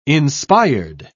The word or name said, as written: inspired